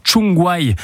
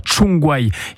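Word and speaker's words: Csongvai: Oui, avec une cinquième recrue pour les Verts, le milieu de terrain défensif hongrois, Aaron Tchungwai. Petit conseil, ça se prononce comme ça. Tchungwai, il a 25 ans, il jouait jusqu'à maintenant en Suède.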